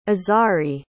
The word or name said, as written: azari